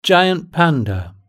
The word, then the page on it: giant panda: British English